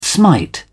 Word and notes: smite: British English

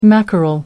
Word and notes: mackerel: American English

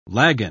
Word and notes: lagan: British English

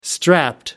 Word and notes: strapped: American English

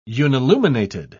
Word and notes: unilluminated: British English

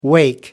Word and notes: wake: American English

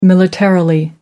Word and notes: militarily: British English

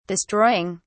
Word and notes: destroying: British English

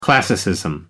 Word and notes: classicism: British English

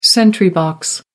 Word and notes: sentry box: American English

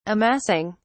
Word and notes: amassing: British English

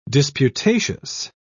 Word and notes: disputatious: British English

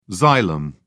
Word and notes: xylem: British English

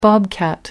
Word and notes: bobcat: British English

